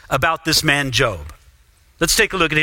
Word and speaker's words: Job: You might see his name and think it's pronounced Job, but we think it's pronounced Job.